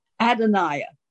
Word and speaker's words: Adonai: That one is, they're all hard for me to pronounce because they're Hebrew, but I know it. Let me give me a moment. It has a different twist to it. Adonai, there it is.